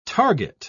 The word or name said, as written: target